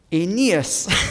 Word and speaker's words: Aeneas: But I like to emphasize the E. Aeneas.